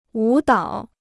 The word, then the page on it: 舞蹈: wǔ dǎo